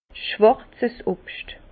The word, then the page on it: schwàrzes Obscht: Bas Rhin; Herrlisheim